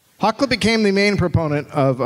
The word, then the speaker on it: Hakluyt: Richard Hakluyt? – Hakluyt. – Okay. – Yeah. I actually looked up the pronunciation of that one. – Proud of you, baby. – Because it's the way it's spelled is fucked up.